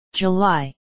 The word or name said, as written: july